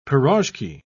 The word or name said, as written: piroshki